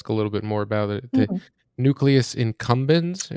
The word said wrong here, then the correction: accumbens: It's accumbens, like A-C-C, accumbens, yeah.